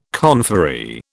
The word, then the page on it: conferee: British English